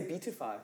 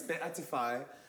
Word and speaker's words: beatify: So it's one of the, like I used to say beatify, but it's beatify.